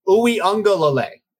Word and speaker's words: Uiagalelei: DJ DJ Uiagalelei. That's how you pronounce it.